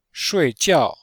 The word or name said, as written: shui4jiao4